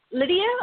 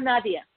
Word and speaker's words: Nadia: Lydia or Nadia? – Nadia, like Nadia, but with the long A.